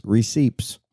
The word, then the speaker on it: receipts: Receipts or receipts? I pronounce the P.